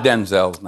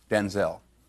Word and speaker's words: Denzel: Because, wait a minute, because my name's not pronounced Denzel. My name's pronounced Denzel.